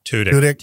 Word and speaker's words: Tudyk: Tudyk.